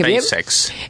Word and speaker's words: SpaceX: que son el SpaceX, ¿cómo se dice? SpaceX, dije SpaceX. – Bien.